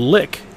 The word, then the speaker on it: lich: There is an argument of whether lich or lick is correct. I prefer Lich, that's what we used back in the day, that's what I still use, because the archaic pronunciation is too easy to get confused with a verb.